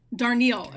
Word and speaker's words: Darnielle: Darnielle, okay.